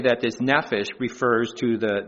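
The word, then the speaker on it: nephesh (נפש): Well, that Hebrew word for soul, that's again, if you look at Strong's Concordance, it's H5315, and it's pronounced nephesh.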